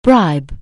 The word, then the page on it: bribe: anglais